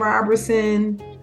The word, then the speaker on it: Roberson: So otenti is the Hebrew for authentic, and that is spelled O-T-E-N-T-I, love, L-O-V-E. Facebook, I'm just Bethlehem Roberson. My last name is R-O-B-E-R-S-O-N, no T's, no I's.